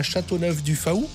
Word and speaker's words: Châteauneuf-du-Faou: Il s'installe à Châteauneuf-du-Faou. C'est bon, je prononce bien, je suis pas... – Ah, c'est très bien. – C'est en Bretagne, je sais pas si on C'est parfait.